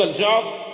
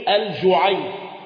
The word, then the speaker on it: الجعد: تنطق هكذا الجعد وبالتصغير الجعيد